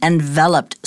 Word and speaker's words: enveloped: Enveloped, like Rhonda said, note the pronunciation of it, because when that is a noun, we say envelope.